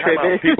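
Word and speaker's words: Tribbett: Depends on which area, which region you're in, but it's Ty Trebet.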